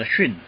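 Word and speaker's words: xun (שׁ): 那钻石跑到右边去了啊，它读 xun 啊，训练的 xun 啊，xun 啊，xun 啊，xun。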